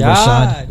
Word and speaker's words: Rashad: Rashad.